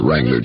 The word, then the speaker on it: Wrangler: Westinghouse portable TVs, Toastmaster hair dryers, Schaefer pen and pencil sets, entry blank at any store that sells Wrangler Jeans. And ask about the free golden key ring inscribed with Wrangler's unforgettable message. Remember, the W is silent.